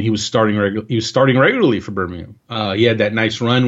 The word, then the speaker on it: Birmingham: It's actually Birmingham, but Birmingham. Did I say Birmingham? – It happens. – I can't even say it right now, but you know what I meant to say. – You have to say it in the British pronunciation.